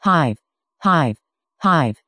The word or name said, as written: hive